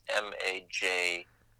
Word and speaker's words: Mejnouni: Mejnouni? – Almost. It's a tough one. So the spelling helps. It's Mejnouni. So Mejnouni.